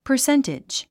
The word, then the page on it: percentage: pə r séntidʒ; パァセンテェジ